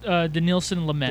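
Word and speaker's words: Dinelson Lamet: How do you say the first name? – Danielson Lamet.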